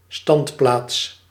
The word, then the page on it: standplaats: /ˈstɑndplats/